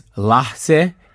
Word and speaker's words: Lahtze: So the show that he presented here is pronounced, and I'm sorry if I pronounce it incorrectly, is Lahtze, and that is Iranian or Farsi for moment.